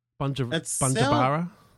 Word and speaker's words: Bujumbura: Did I pronounce that correctly, Scott? Bujumbura? – I reckon pretty good, the last one. The Bujumbura. First one had a bit of... that was good too.